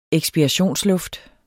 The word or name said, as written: eksspirationsluft